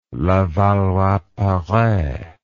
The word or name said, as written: Levallois-Perret